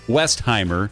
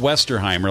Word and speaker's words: Westheimer: It's Ruth Westheimer. It's not Westerheimer. A lot of people say that. We put an extra syllable in there, but it's Dr. Ruth Westheimer, 92.